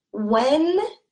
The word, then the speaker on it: Wednesday: And this first D is going to be silent. And it sounds like Wednesday. Wednesday. And this S has that Z like sound. So silent D, Wednesday.